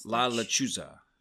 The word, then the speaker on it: La Lechuza: You're going a little heavy on your S's there. It's with a Z, not an S. – La Lechuza.